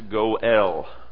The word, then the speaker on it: goel: It is a four-letter word, and the word is pronounced Goel, G-O-E-L.